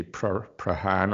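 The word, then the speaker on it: Prahran: As a Scottish person, we'd probably say Prahan or something, but I think the locals say Prahan.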